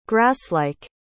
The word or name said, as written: grasslike